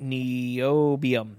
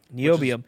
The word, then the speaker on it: Niobium: Niobium. Is that how you pronounce that? – Niobium. Niobium.